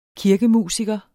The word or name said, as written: kirkemusiker